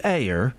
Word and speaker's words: Eyre: How do you say that? E-Y-R-E. Eyre. – Eyre. – Oh, what's that? – Like Jane Eyre.